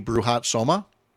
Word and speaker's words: Bruhat Soma: Yeah, I was just looking at the coverage here in the Washington Post, and the champion, who's now 12 years old, maybe you know the pronunciation better than me, Bruhat Soma. – Yep, that's was the name.